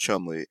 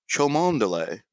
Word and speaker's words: Cholmondeley: Chumley. It's spelled Cholmondeley. It looks like it should be pronounced Chomondeley. – Yeah, there's got to be some more syllables in there. – It's Chumley.